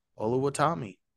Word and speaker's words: Oluwatomi: It's Olusegun Oluwatomi. – Oluwatomi. Yep.